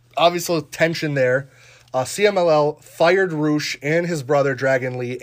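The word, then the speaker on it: Rush: Roosh is how it's pronounced.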